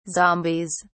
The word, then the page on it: zombies: British English